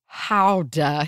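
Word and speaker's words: Gouda: I can indeed confirm that the cheese is pronounced howda here with a guttural GH sound, which, yes, I know I didn't do that, but I gave an attempt.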